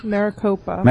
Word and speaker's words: Maricopa: According to the ACLU, Apio's how do you say that county? – Maricopa.